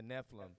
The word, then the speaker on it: Nephilim: So the Nephrim, the Nephrim, I think they call called the Nephrim. – I'm, I'm probably butchering that name. The Nephilim. Nephilim.